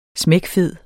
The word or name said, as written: smækfed